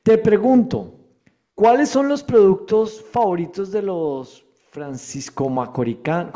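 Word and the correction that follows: francomacorisanos: ¿Cómo se dice? San Francisco Franco Macorizanos. – Franco Macorizanos, perdóname.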